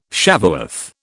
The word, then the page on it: Shavuoth: British English